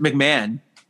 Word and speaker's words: McMahon: Oh, how do you say it? – It's actually pronounced McMahon. – Oh, really? – Yeah. – I did not know that. – Oh, well, you're welcome. Julian McMahon is how you actually say it, but it has that funny spelling.